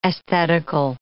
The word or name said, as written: aesthetical